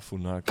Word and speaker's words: Funaki: Ich lerne es jetzt nochmal. Funaki? – Ja, Funaki.